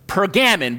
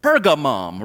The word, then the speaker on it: Pergamum: Like if you the letter from last week, if you don't have the proper pronunciation, you would think it's Pergamon, but it's Pergamum, right?